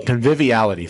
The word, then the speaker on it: conviviality: Convivability. Am I doing that right? – Conviviality? – Conviviality. Thank you.